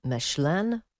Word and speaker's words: Mechelen: I don't know how to pronounce this, but it's spelt M-E-C-H-E-L-E-N. Mechelen? – Should we do some alternatives? Mechelen? – Mechelen. Yes. – Mechelen. – Yeah, that is more likely. I think the first one sounded more likely.